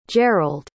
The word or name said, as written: gerold